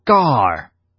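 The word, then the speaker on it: gar (γάρ): 第七节的第一个字在希腊文当中读作 gar，意思是因为。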